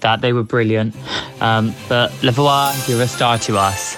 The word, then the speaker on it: La Voix: Is the actual pronunciation of that. I called her Lavoie, but it's actually Lavoie, as in voila.